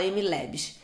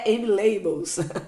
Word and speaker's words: MLabs: É que fala MLabs. As pessoas já falaram até MLabels.